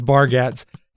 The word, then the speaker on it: Bargatze: I think it's pronounced Bargatze.